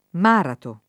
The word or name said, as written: Marato